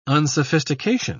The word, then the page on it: unsophistication: British English